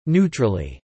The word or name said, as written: neutrally